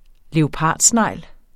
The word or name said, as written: plettet